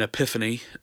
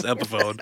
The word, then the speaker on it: Epiphone: And so I went out and bought a guitar, an Epiphany guitar, which is how I thought you pronounce Epiphone.